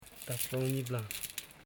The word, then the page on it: taflanyibla: ta.fla.nyi.bla